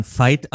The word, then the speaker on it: Veit: Ich habe immer Veit Lindau gesagt. Dann wurde in meiner Mastermind mir beigebracht, ich spricht man Fight aus. Wie wurde das englische Wort Kampf, Fight, dazu nahegelegt?